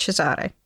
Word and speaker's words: Cesare: and Cesare, which is spelt Caesar, but pronounced Cesare.